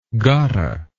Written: gotta

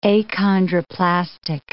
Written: achondroplastic

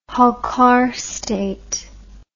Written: holkar state

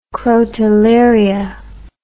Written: crotalaria

